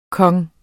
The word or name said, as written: konge